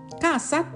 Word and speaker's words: casa: ガーザ家ですガーサってね濁らないで発音しても大